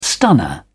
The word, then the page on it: stunner: British English